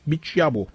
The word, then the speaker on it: Bichiabu: Bu arada doğru telaffuz ettim değil mi? El Shaddai Bichiabu.